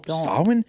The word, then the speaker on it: Samhain: Samhain? There's not even a W in it. – That's how it's actually pronounced.